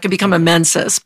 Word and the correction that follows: Mensa: Mensa. – Yeah. I knew I wasn't saying that right.